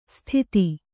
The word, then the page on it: ಸ್ಥಿತಿ: sthiti